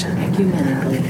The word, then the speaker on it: ecumenically: Okay, tell me how to say this in English. – Ecumenically. – Thank you. Ecumenically minded.